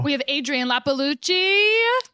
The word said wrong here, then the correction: Iapalucci: That's not my right. – I said it wrong. – It's Appalucci. – Everyone always does that. – It's Appalucci. – It's Appalucci. Yeah. But everyone thinks it's Lapalucci.